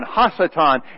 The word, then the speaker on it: Ha-Satan: Or Satan is the actual name, if you want to say it correctly. Satan, Ha-Satan.